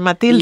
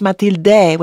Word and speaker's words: Matilde: Tilly is short for Matilde. – You don't like Matilda. – People don't know how to say it right in Spanish. Ah. It's Matilde with an E on the end instead of Matilda.